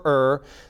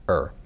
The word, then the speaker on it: err: The Bible can no more fail, falter, or err than God Himself can fail, falter, or er, or air, depending on how you'd like to pronounce E R R. Say er.